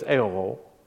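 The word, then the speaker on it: Ahio: So he has this huge crowd, and they put it on the new cart, and Abinadab's sons, how do you say it? It's like Ohio, but it's Aho, and Uzzah.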